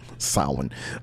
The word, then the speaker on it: Samhain: Oddly enough, it's pronounced Samhain. Who knew? – Right. Sawin. I've been on top of the Empire State Building and the Chrysler Building.